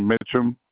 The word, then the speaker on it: Mitchum: Jermaine Mitchum.